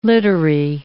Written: littery

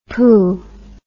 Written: poo

